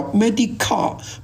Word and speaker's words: Medicare: 但是这个好像还有一种保险，有的美国还有一种保险呢更加复杂，这个保险呢跟联邦和州办的这个字啊跟那个 Medi-Cal 差不多，但是这个读音的不同，叫 Medicare。 Medi-Cal 是 Medical Care 这个字，所以叫 Medi-Cal。 Medi-Card 这个 C-A-I-D 还是 Card 是两个东西，这个 Medi-Card 呢是联邦和州一起提供的医疗卫生计划，这是另外一个，就是为了某些收入资源有限的人提供的医疗费用的协助。